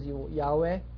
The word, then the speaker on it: Yahweh: Some of you find it in the Old Testament, YHWH, we pronounce as Yahweh.